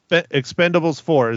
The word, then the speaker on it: Expend4bles: But it's Expendables 4 is how I am pronouncing it.